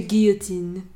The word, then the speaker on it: guillotine: How do you say that word? Because they say guillotine in the song. – Because they're not French. – Mm-hmm. We are French. C'est guillotine.